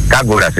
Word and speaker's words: Kangwa: αλλά σήμερα ολοκληρώθηκε και η μεταγραφή του Kings Kangwa στο τριφύλι. Kangwa. Kings Kangwa. – Φίλε, δεν ξέρεις να το προφέρεις. Kangwas είναι ο άνθρωπος.